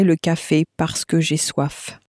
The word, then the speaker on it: soif: And it's spelt S-O-I-F. The word for hunger was... – Faim. – Spelt F-A-I-M. Now, you may notice that the F on the end of soif was very clearly pronounced.